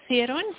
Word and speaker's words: Theron: It's M-E-N-I-E. – Yep, and your surname? – And then Theron, T-H-E-R-O-N. – And what is your email address? – It's MB, then the Theron, T-H-E-R-O-N.